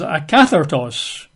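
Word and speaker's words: akathartos (ἀκάθαρτος): The word common in the Greek is spelled K-O-I-N-O-S, K-O-I-N-O-S, like koinos. I think it's pronounced kinos. And unclean is akathartos, okay? A-K-A-T-H-A-R-T-O-S, A-K-A-T-H. A-R-T-O-S, akathartos.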